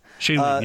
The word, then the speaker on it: Shailene: And if you believe the rumors, how do you pronounce it? Shailene? – Shailene, yeah.